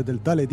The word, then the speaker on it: Telltale: Sí, porque al principio lo has dicho bien y ahora has cagado. – Telltale.